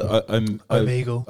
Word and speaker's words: Omegle: Omegle.